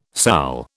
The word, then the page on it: sal: British English